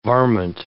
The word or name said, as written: varmint